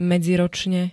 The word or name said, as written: medziročne